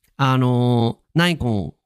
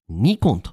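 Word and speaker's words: Nikon: なので皆さん、ナイコンを日本人っぽく発音する場合は、ニコンと発音してみてください。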